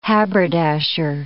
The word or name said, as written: haberdasher